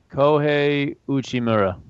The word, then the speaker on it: Kohei Uchimura: Kohei Uchimura.